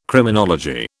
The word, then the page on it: Criminology: British English